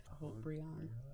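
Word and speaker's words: Haut-Brion: how, do you, how do you say that? Hot Brion. Okay.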